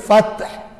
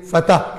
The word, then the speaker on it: فتح: تم میں سے جنہوں نے فتح, یہ لفظ اصل میں عربی میں فتح تے ساکن, لیکن ہم اردو میں تلفظ میں آسانی کے لیے فتح کہہ دیتے ہیں,